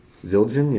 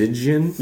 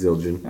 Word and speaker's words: Zildjian: That's how you say it? – Zildjian, yeah. – Oh, wow. I didn't realize there was an L there. – You thought it was Zildjian? – Yeah. – No, it's Zildjian.